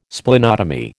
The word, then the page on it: Splenotomy: American English